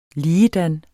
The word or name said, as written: ligedan